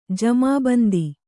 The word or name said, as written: ಜಮಾಬಂದಿ